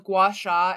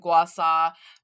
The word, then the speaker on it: gua sha: And I've heard it pro- pronounced both gua sha and gua sa by different different Chinese practitioners. I think it probably has to depend. It depends on the region, but the facial massaging tools, um, where you do like the scraping massage.